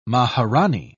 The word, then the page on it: maharanee: British English